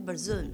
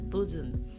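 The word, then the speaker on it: Burzum: 就是我们常常讲的那个有人念 Bruzum，但其实他是 Bruzum。对，就北欧他们那边当地的发音是这个样子。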